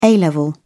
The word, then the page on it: a level: British English